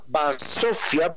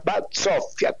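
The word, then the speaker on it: bazzoffia: Entonces, él me decía, me dijo, que la palabra BATSOFIA, BATSOFIA con Z, así como pronunciarían los italianos, viene de dos em, expresiones.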